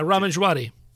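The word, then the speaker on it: Ramin Djawadi: The music is by, I'm going to need some help with this one, Raman Djawadi? – Yeah, Raman Djawadi.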